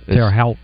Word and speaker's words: Terre Haute: And Terre Haute, is that how you say it? Terre Haute or Terre Haute? I don't even know where it's at. – Terre Haute.